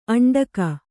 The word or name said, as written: ಅಂಟಿಕ್ಕು